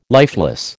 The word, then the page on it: lifeless: American English